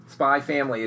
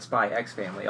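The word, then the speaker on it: Spy × Family: Did you know this, that you're not supposed to pronounce the X in these things? – No, I didn't. – Spy Family is just Spy Family, not Spy X Family.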